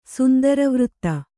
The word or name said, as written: ಸುಂದರವೃತ್ತ